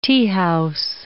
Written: teahouse

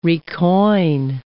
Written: recoin

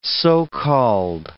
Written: so-called

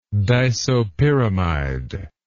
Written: disopyramide

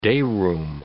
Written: dayroom

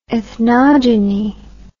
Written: ethnogeny

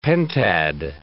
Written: pentad

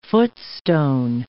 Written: footstone

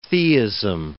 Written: theism

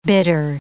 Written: bitter

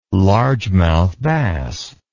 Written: largemouth bass

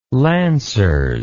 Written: lancers

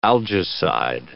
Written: algaecide

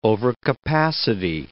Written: overcapacity

